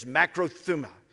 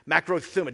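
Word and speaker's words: makrothumia: And here's how you say this, makrothuma. Makrothuma. It doesn't look like it, but that's how you would pronounce this.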